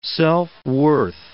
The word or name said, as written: self-worth